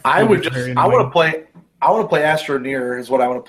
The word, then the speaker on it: Astroneer: I think it's pronounced Astroneer. – You're right, my bad. Astroneer.